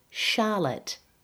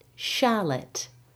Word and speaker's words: Charlotte: Remember that it's written with a CH, but we pronounce it as SH, like SH, not CH. It's Charlotte. So it's similar to champagne and Chicago. These words are spelt with the letters CH, but the CH is pronounced as SH, not CH. The second vowel in the word Charlotte is schwa.